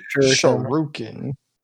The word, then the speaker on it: shuriken: Shuriken.